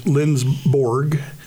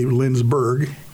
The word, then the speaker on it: Lindsborg: And then there's Lindsborg, which people say Lindsberg.